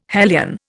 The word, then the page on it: hellion: Indian